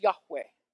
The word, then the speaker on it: Yahweh: The reason that's in all caps is that is the holy name as revealed by God the pronunciation we typically hear is it's pronounced Yahweh.